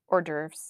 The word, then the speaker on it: hors d'oeuvres: Hors d'oeuvres. How do you say that? – Hors d'oeuvres. – Yeah. – Horvesy d'oeuvres. – Horvesy. – I do call them horvesy d'oeuvres. Like this, like the Swedish Muppet. The Swedish chef.